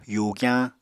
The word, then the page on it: yugyañ: Apatani